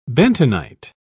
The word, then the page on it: bentonite: British English